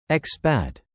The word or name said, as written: Expat